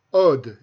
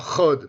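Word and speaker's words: Hod: Une première, j'ai fait une, erreur de prononciation à propos d'une des séphirotes, qui est la huitième séphira, qui se prononce Od et non pas Chod, parce que elle, va avec la séphira Netzar.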